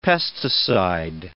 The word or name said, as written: pesticide